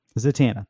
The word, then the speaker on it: Zatanna: Zatanna, thank you, is going to say that she needs your help to find John Constantine.